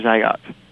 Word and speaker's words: Zagat: So now what they've done is they've switched it on so that city search information and Zagat, is it Zagat or Zagat? – Zagat. – I don't know how to pronounce it.